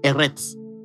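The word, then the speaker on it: eretz (ארץ): eretz, eretz, something like that. It's pronounced like eretz.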